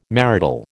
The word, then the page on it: marital: American English